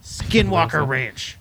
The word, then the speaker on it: Skinwalker Ranch: Skinwalker Ranch.